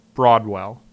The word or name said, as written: Broadwell